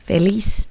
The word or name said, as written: feliz